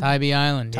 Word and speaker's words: Tybee Island: Oh, it's how do you pronounce it? Savannah? Tybee Island? Isn't that in Savannah? – Tybee Island, yeah.